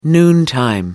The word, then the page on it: noontime: British English